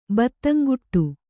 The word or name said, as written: ಬತ್ತಂಗುಟ್ಟು